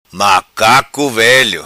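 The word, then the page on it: Velo Macaco: patua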